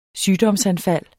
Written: sygdomsanfald

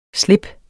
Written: slips

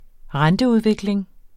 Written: renteudvikling

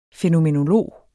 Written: fænomenolog

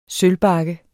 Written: sølvbakke

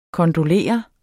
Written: kondolere

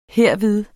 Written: herved

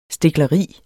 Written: stikleri

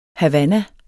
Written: havanna

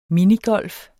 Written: minigolf

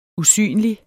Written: usynlig

